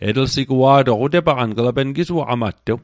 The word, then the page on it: sigua'toq: Mi'gmaq